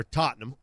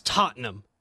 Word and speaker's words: Tottenham: Real quick, it's Tottenham. It's not Tottenham. That's not how it's said.